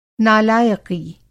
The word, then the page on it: नालायक़ी: Hindi